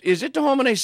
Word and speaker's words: De Homine: Did I say that right? Is it De Homine 16? – Yeah, it's right.